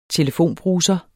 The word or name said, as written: telefonbruser